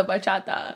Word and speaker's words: bachata: The bachata. That's how you say it. The bachata.